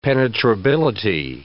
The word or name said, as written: penetrability